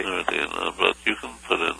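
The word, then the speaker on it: Nooradin: N-O-O-R. Actually, it's Nuradin. – N-U? R-A-D-D-I-N? – N-O-O-R-A-D-I-N, I guess. Or D-E-E-N.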